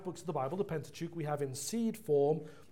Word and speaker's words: Pentateuch: Children, the Pentateuch is a good word for you to memorize. The Pentateuch, simply, If you think about the word pent, you hear the first syllable there, pent.